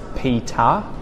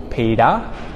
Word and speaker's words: Peter: my name is Peter or Peter, whereas Americans would say Peter or Pidar, not even Pidar, they'd say Peter, Peter, hey Peter.